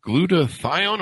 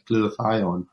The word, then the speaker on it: Glutathione: Glutathione. Am I pronouncing that correctly? – That is exactly right. Glutathione.